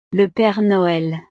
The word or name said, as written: le pere noel